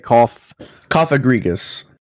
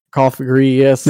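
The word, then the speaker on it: Cofagrigus: Coffigrigus. – Coffigrigus.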